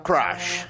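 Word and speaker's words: crush: Crash. Que se pronuncia crash.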